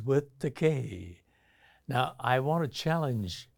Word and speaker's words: Takei: It's pronounced Takei. However, Takei is a legitimate Japanese word, which means expensive. So they'll have to pay up if they want to insist that I'm expensive. But Takei rhymes with other words like day, say, may, play, gay.